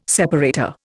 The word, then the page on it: separator: Indian